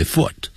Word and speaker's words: foot: 第二个，借由交通工具或某种方式， I often go to work or go to school by bus, by train, by car, by plane, by ship，但是走路的时候就不能用 by foot，要用 on foot， F-O-O-T， on foot，不要念成 foot， foot， foot，跟 book 发音一样。